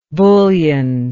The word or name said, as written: bullionless